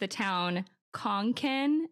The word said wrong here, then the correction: Concan: and apparently it's Concan. Okay. Like like a yeah. can of soup.